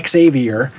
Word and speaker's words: Xavier: First of all, Christina and Xavier, it turns out I did say it wrong. I said Xavier, I think. It's Xavier. And he said that it's like pronouncing the first three letters, X-A-V, and then R.